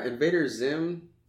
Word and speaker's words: Invader Zim: Any trailers I didn't mention you noticed that Invader Zim, if I'm saying it right.